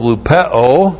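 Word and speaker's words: lupeo (λυπέω): The articular participle of lupeo L-U-P-E long O lupeo.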